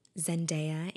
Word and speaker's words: Zendaya: Zendaya and John David Washington, directed, written, produced by Sam Levinson, and I think Zendaya is also a producer.